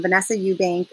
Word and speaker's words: Vennesa Eubank: Yeah, so my social media is um, Vennesa Eubank, but my name is spelled super interesting um, V E N N E S A E U B A N K.